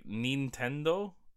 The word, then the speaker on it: Nintendo: You mean Nintendo? – I mean Nintendo. – Why'd we change it? – Because we pronounce it like we see the letters. We don't make an E sound for an I.